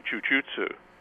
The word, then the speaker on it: jujutsu: Japanese, the right pronunciation, incidentally. – Was it Japanese jujutsu or was it Brazilian? – Jujutsu, yeah, just traditional Japanese jujutsu. – Mm-hmm. – That. – You pronounce it jujutsu.